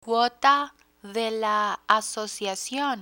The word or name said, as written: cuota de la asociacion